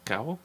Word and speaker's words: cacao: C-A-C-A-O? – Creme de cocoa. – Cacao? – It's not cocoa, dumbass. Cacao? – It's chocolate.